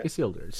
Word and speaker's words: Isildur's: Isildur's sounds good.